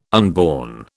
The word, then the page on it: unborn: British English